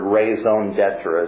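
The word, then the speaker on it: raison d'être: If you're a reader who occasionally comes across a strange spelled word, raison d'etre is how it's pronounced, your reason for existence, the justification for why you exist, is that God?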